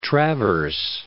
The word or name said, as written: Grand Traverse Bay